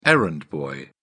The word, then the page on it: errand boy: British English